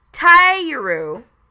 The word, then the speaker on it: Tyurru: And then we've got a character called Tyuru, and that's spelled T-Y-U-R-R-U, and she's 12.